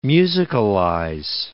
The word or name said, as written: musicalize